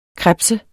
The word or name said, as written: krebse